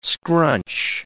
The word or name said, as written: scrunch